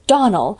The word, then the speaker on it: Donnell: "I'm sorry," faltered Anne, vainly trying to recollect any incident of the morning connected with the Donnell children. – "Clarice Almira told me that you pronounced our name Donnell. Now, Miss Shirley, the correct pronunciation of our name is Donnell, accent on the last syllable.